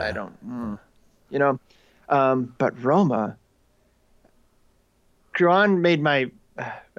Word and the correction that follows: Cuarón: Curran. is that how you say his name? – Alfonso Cuarón.